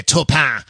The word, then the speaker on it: Taupin: Well, you know, as we were chatting this week, I don't know if you know that, but Bernie Taupin, I pronounce it the French way, Taupin.